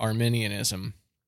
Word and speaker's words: Arminianism: Arminianism. Did I say that right? – You said that right.